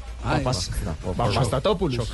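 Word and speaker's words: Papastathopoulos: Papastatopoulos.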